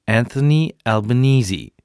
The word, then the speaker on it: Anthony Albanese: Anthony Albanese.